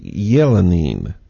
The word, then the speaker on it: Elenin: It was named Elenine, E-L-E-N-I-N. It is pronounced, actually, Yelenine, if you pronounce it correctly. It's Russian.